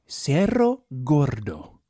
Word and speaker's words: Cerro Gordo: I'm sure you probably roll the R's, I would imagine, but I can't. Okay. Can you? You can roll your R's. – You want me to say it? – Cerro Gordo. – Is it C-E-R-O? – C-E-R-R-O and then G-O-R-D-O. – Oh, Cerro Gordo. – I bet you that's how it's pronounced.